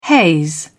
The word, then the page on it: haze over: British English